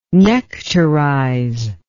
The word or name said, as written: nectarize